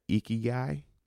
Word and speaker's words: ikigai: In Japanese, they call it ikigai. I don't know if I talked about this in the last podcast, but ikigai is your reason for getting up in the morning. It's spelled I-K-I-G-A-I, ikigai.